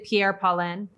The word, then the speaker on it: Pierre Paulin: tell me if I'm even saying this right, but is it Pierre Paulin? Yes.